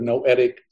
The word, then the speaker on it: Noetic: What is Neonic Sciences? Did I even pronounce that right? – I'm sorry. The Institute of Noetic Sciences. – Noetic. – It was founded by the late astronaut Edgar Mitchell. – Noetic. All right, there you go.